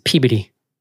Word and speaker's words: Peabody: It's Peabody.